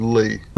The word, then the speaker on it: Leah: But anyway, she shows up at their house because Leah, or is it, did you pronounce it Lee or Leah? It's spelled Leah. – I thought they said Lee.